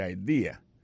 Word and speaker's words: idea: Ou gen wè di, that's a good idea, T-H-A, T, apostrophe S, good, G-O-O-D pour good, ça veut dire bon, idea, c'est I-D-E-A, ça veut dire idée, that's a good idea. Alors, accent vient tomber dans la deuxième syllabe là, one idea.